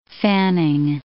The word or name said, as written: Fanning